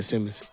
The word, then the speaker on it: Simons: Did I say that right? Simons? Simons? – Yeah, Simons. – Simons. It's spelled weird.